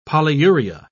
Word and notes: polyuria: British English

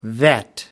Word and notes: vet: American English

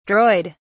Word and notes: droid: British English

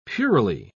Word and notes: puerilely: British English

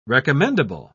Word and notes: recommendable: British English

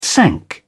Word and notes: sank: British English

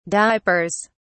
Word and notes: diapers: British English

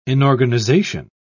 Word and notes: inorganization: British English

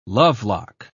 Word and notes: lovelock: British English

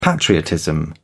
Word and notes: patriotism: British English

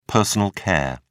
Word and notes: personal care: British English

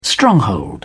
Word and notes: stronghold: British English

